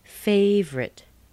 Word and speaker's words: favorite: Favorite. Favorite. Favorite. Do you hear that O? Neither do I. There's no O there. Two syllables, not three.